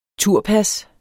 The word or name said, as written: turpas